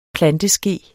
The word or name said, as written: planteske